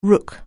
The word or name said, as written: rook